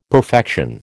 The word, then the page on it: perfection: American English